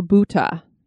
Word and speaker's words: bhuta: The Indian people have a particular term they use for a ghost, and that is bhoot or bhuta, and that's spelled B-H-O-O-T or B-H-U-T-A.